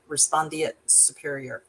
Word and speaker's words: respondeat superior: And I didn't pronounce that wrong. It's a Latin term, respondeat superior.